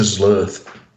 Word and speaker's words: Zlurth: He played a character called Dominator Zlurth.